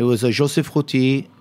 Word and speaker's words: Roty: Joseph Routy, R-O-T-Y. – O-T-Y, which is a favorite of yours.